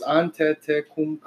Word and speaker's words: Antetokounmpo: Antetokounmpo. Five syllables. Antetokounmpo.